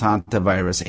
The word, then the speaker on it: hantavirus: So is it H-A-U-N-T-A? – H-A-N-T-A. H-A, like Santa Claus, but it's pronounced hantavirus. H-A-N-T-A virus.